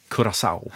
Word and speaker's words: Curaçao: Jag har fått stöd av producenten att man ska tydligen uttala det Curaçao.